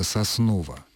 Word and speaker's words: Сосново: Поэтому, когда диктор объявляет «следующая остановка Соснова», то он именно акает, потому что в первом слоге название «Соснова» звучит звук ближе, напоминающий именно «а».